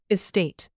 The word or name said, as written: estate